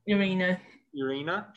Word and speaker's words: Irina: Irina, just making sure I'm pronouncing that.